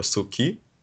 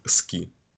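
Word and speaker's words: ски (好き): Если пример тоже, то вот слово «любить» э, будет у нас записываться как «ски», либо «суки», то произносить мы будем это без вот этой «у», как «ски».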